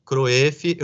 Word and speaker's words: Kroeff: Bom, meu, meu nome é Daniel Crefe, Kref se escreve K-R-O-E-F-F, K-R-O-E-F-F, Cruef. Eu estou em todas as redes sociais, no LinkedIn, no Instagram é D Cref, K-R-O-E-F-F.